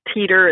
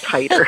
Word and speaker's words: titer: And it's not teeter, it's not titter, it's titer. And it is spelled two ways, T-I-T-E-R, or I think it's the British spelling, which is T-I-T-R-E.